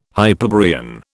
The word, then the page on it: Hyperborean: British English